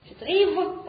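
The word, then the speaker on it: rive: Она не читается на конце французских слов, если над ней нет значков. «Рив» – последнюю букву зачеркиваем, «Гош» – последнюю букву зачеркиваем.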